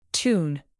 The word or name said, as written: tune